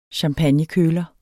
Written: champagnekøler

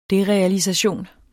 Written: derealisation

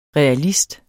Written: REALISTER

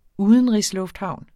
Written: udenrigslufthavn

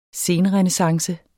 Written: senrenæssance

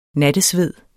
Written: nattesved

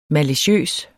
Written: maliciøs

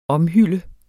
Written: omhylle

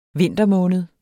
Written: vintermåned

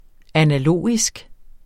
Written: analogisk

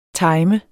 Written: timer

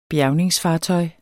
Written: bjergningsfartøj

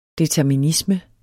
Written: determinisme

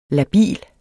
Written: labil

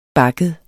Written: bakket

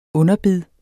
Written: underbid

